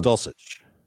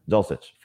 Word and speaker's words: Dulcich: Is that am I saying that right? From UCLA? – I believe it's Dulcich. – Dulcich from UCLA.